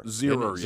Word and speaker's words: Zierer: Apparently, okay, it's two syllables. – Zierer.